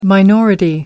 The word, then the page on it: minority: American English